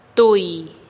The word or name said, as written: tui